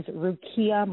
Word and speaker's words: Rukiya: My name is Rukiya Martin under the um, Ink magazine cover shoot.